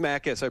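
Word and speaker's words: Makis: Dr. William Macus, or Macus. Mm-hmm. And yeah, it is Macus. I pronounced that wrong.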